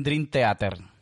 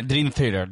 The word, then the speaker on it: Dream Theater: Mi, camisa es mi banda favorita, A en Argentina le dicen Dream Theater. Nah, Dream Theater.